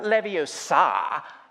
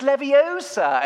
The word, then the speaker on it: Leviosa: He's saying it all wrong, and his know-it-all friend Hermione comes over and says, "It's not Leviosa, it's Levio, you know, it's not, it's not Leviosa, it's Leviosa. And then she does it and it's perfect. It's just, he had the words right, but it's the pronunciation.